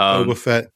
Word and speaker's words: Boba Fett: You did, Boba Fett.